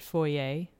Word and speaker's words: foyer: I think in Europe, they're more apt to pronounce it foyer. – Yes. – With the French pronunciation. And I think that's probably why here, New Brunswick being a bilingual province, we tend to pronounce it in the French way as well. The right. ER as A rather than ER.